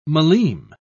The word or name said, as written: millime